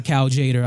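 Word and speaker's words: Cal Tjader: I got my Cal Jader. I got, did I say his name right? – Yeah, you did.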